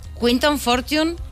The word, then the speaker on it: Quinton Fortune: Quinton Fortune, ¿lo hemos pronunciado bien? – Sí. Bueno, aunque normalmente en España es Quinton Fortune, pero es igual.